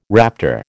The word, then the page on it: raptor: American English